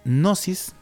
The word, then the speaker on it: gnosis: y conocimiento en muchas partes es gnosis, que se escribe G-N-I perdón, G-N-O-S-I-S, que es gnosis, ¿ok? Con una G al principio.